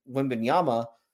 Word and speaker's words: Wembanyama: They might be, just because it's not like, obviously the Spurs added Wembanyama. Did I say it right this time? I don't know. So they added him. I think it was close enough. We'll give you the thumbs up.